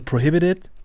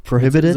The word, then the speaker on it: Prohibited: Also so richtig Englisch wäre eigentlich so Prohibited. – Prohibited, so mit so einem D quasi schon statt des Ts.